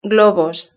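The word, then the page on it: globos: Spanish (Spain)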